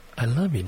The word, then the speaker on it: Elilu: Elilu Jenny. That is pronounced as I love you, Jenny.